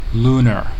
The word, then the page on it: lunar: American English